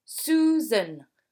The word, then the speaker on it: Susan: Susan, in English, se prononce Susan, et pas Suzanne, comme le prénom français.